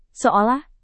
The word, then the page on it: seolah: Indonesian